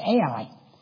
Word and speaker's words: Ai: Between Bethel and that's spelled Hai, but it's Ai.